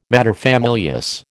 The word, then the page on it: materfamilias: American English